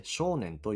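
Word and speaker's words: 正念: 漢字では正しい念と書いて少年と読みます。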